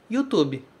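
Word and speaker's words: YouTube (ユーチューブ): É assim que o japonês pronuncia YouTube, ok? Tu, esse tu é importante e o bu no final, certo? Em português, a gente fala bi, YouTube, como se tivesse um i no final.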